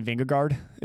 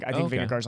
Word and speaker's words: Vingegaard: I think Vingegaard's like 25. – Is it Vingegaard or it looks like Vingegaard, but I think it's pronounced Vingego.